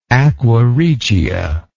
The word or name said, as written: aqua regia